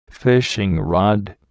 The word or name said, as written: fishing rod